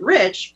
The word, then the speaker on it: Reich: And our last name was R-E-I-C-H. We pronounced it Rich,